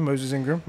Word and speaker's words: Moses Ingram: It's her name is, maybe I'm not saying it right, it's Moses Ingram. – That is it. That is her name, Moses Ingram.